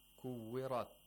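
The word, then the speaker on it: كُوِّرَتْ: And in these verses, we have many ra's that are pronounced with tafkhim. Kuwirat.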